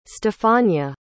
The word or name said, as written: stefania